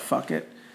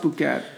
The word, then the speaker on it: Phuket: It's spelled like Phuket. So when I was growing up, people used to call it fuck it. But it's pronounced Phuket.